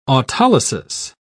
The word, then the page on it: autolysis: British English